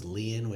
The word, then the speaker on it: Lian: And it's Leanne, L-I-A-N, not Liam, like Liam yes, Hemsworth. – L-I-A-N.